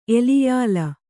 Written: ಎಲಿಯಾಲ

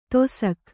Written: ದೇಸಾಕೆ

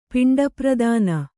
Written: ಪಿಂಡಪ್ರದಾನ

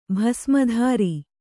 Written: ಭಸ್ಮಧಾರಿ

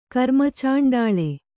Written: ಕರ್ಮಚಾಂಡಾಳ

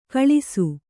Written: ಕಳಸ

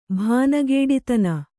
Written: ಭಾನಗೇಡಿತನ

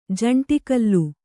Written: ಜಂಟಿಕಲ್ಲು

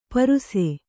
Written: ಪರುಸೆ

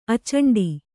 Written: ಅಚಂಡ

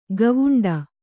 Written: ಗವುಂಡಿ

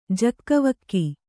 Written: ಜೋಗವೋಗು

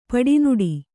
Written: ಪಿಡಿನಡೆ

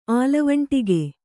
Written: ಆಲವಂಟಿಗೆ

